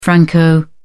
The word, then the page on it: franco: British English